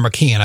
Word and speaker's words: McKeon: Actually, it can be pronounced McEwen or McKeon. I've actually heard both pronunciations,